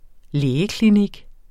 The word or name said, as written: lægeklinik